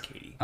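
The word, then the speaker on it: Cady: It's spelt like Caddy, but yeah. It's Katie.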